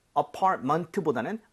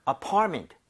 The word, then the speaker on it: apartment: So 강세를 제대로 주면 MENT가 그렇게 발음이 돼요. 여기 강세 주고 한번 해보면 네. – apartment보다는 apartment, apartment.